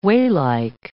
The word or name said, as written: wheylike